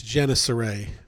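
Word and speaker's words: Gennesaret: It's Genesaret.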